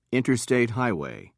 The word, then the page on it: interstate highway: British English